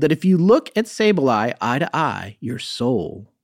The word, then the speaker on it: Sableye: Yeah, Sableye.